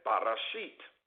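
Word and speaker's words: Bereshit: Genesis is produced, or it is pronounced as Barashit.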